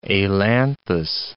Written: ailanthus

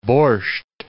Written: borsch

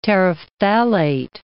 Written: terephthalate

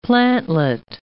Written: plantlet

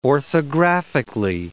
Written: orthographically

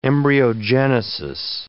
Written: embryogenesis

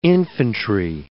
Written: infantry

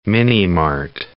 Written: mini-mart

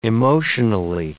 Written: emotionally

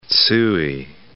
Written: Tsui

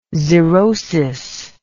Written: xerosis

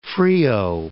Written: Frio, Cape

